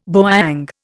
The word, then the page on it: bowyang: Indian